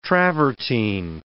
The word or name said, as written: travertine